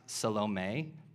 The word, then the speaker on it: Salome: By the way, the name Salome, it's technically pronounced Salome, but it sounds way too much like salami, and I didn't want to make you guys like drool while I was preaching and thinking about lunch.